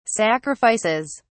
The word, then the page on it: sacrifices: British English